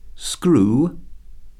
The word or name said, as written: screw